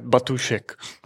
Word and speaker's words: Batůšek: Almost, Robert Batůšek.